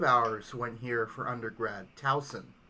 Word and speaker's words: Towson: Townsend? – It's pronounced Towson, but... or Towson, I'm not sure. – I think it's Towson. Shin's like, something Eagles? Yeah, exactly. – But it's... It's spelled T-O-W-S-O-N, like Towson.